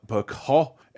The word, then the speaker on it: Picot: you can't pronounce that T, can you, with a name like Genevieve, Picot, and Russell Crowe in Proof.